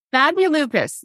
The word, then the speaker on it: Fabulupus: And I always go to say it and I never say it correctly. Fabulupus. Fabulupus? – Fabulupus. Yes.